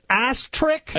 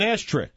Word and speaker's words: asterisk: An ass trick? – An asterisk.